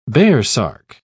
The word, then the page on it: baresark: British English